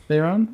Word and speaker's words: Veyron: Is that how you say it? Veyron? Veyron? – Veyron? Yeah.